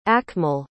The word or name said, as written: akmal